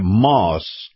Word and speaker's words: Maus: And by the way, brought to you by Moss Nissan. Moss is spelled M-A-U-S.